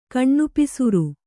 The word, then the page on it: ಕಣ್ಣುಪಿಸುರು: Kannada